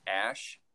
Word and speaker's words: Æ: Yeah, and then the AE you just pronounce as ash.